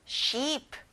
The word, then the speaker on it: sheep: 양, sheep. E가 두 개라서 길게 내는 겁니다.